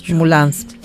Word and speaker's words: Moulins: Moulins, así es la pronunciación en Francia.